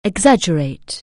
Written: exaggerate

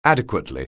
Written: adequately

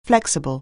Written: flexible

